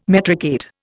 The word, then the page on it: metricate: Indian